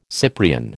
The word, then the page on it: Cyprian: American English